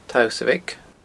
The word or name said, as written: tausabhaig